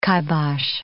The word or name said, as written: kibosh